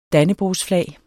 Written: dannebrogsflag

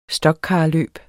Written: stockcarløb

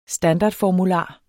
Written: standardformular

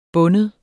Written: binde

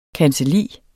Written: kancelli

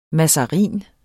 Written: mazarin

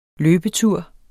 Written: løbetur

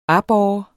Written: aborre

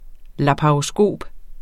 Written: laparoskop

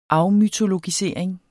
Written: afmytologisering

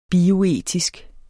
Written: bioetisk

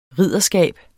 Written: ridderskab

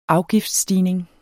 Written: afgiftsstigning

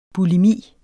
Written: bulimi

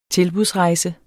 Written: tilbudsrejse

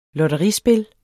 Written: lotterispil

